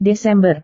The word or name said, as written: desember